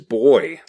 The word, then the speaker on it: buoy: I mean, I feel like for me, the big revelation is that people in England pronounce buoy as boy.